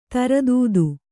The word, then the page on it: ಧೀರೋದಾತ್ತ: dhīrōdātta